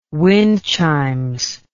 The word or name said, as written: wind chimes